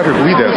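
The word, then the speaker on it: Urquidez: Well, I'm with Benny the Jet Urguidez.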